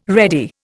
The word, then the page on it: ready: Indian